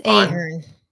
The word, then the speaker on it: Ahern: It's Ahern.